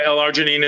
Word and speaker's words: L-arginine: L-arginine. Am I saying that right, Nathan? – Yeah, L-arginine is an amino acid.